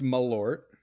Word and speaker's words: Malort: He is having some Marlott. He is our friend. How are we? – Doing great. It is pronounced Malort.